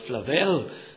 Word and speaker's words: Flavel: The great Puritan preacher John Flavel, or Flavel, as some pronounce his name, had an amazing experience on one occasion.